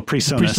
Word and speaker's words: PreSonus: Oh, Presonus.